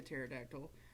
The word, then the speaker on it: pterodactyl: I want to be a pterodactyl. – Is it spelled with a PT? The silent P? – No, I call it pterodactyl.